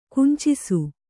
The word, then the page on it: ಕುಂಚಿಸು: kannada; kuncisu